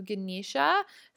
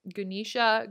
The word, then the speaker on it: Ganesha: Some people pronounce his name differently. So I've heard like Ganesh. I've heard Ganesha. It's Ganesh or Ganusha or I don't know, Ganesh. I've heard it pronounced so many different ways.